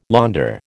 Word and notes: launder: American English

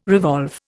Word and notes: revolve: Indian

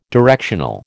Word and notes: directional: American English

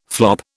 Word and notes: flop: British English

